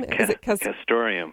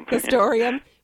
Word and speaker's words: castoreum: Castoreum? – Castoreum. – Castoreum, which is derived from beavers' anal glands.